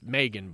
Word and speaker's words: M3GAN: It's Megan, but it's spelled like that.